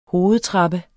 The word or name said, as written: hovedtrappe